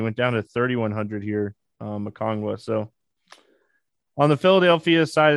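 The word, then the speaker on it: Okongwu: Okongwa, I said it right for Will this time. He corrected me yesterday.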